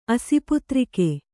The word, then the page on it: ಅಸಿಪುತ್ರಿಕೆ: Kannada